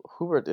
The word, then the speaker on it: Hubert: And Hubert is actually a German name. I have a friend named Anika who told me the German pronunciation years ago, which is Hubert. So Hubert is German,